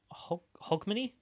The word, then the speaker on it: Hogmanay: The reason Hogmanay is...